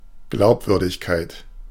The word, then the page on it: Glaubwürdigkeit: /ˈɡlaʊ̯pˌvʏʁdɪçkaɪ̯t/